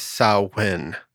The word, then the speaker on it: Samhain: And I do want to, sorry to interrupt you, but the proper pronunciation of that, even though it is spelled that way, is Samhain. – Samhain, okay. – Samhain, even it's spelled Samhain. Oh, yeah. And I've always heard it that way, but it's Samhain.